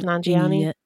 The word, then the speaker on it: Nanjiani: Is that how it's pronounced? Nanjiani? – Yes.